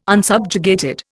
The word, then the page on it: unsubjugated: Indian